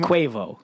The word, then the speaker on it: Quavo: Quavo. – Quavo.